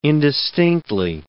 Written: indistinctly

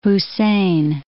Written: Hussein I

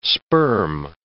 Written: sperm

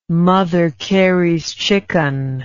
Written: mother carey's chicken

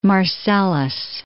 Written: Marcellus

